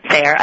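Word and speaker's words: Sahre: You're married to Paul Sayre. – Sayre. – And it's funny because I have this, like, embedded in my brain to say it wrong.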